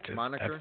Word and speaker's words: moniker: Um Whoa, what was that? – Monkey Air. that, moniker? If I believe. – Is that how you say it, moniker? Mm-hmm. You sure? – I am an English major, but yes, it, it, so many great messages to kind of go um, along in this video.